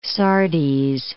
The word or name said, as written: Sardes